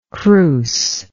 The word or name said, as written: crousely